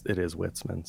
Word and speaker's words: Witzemann: But of course, you did say the name wrong. It is Witzemann.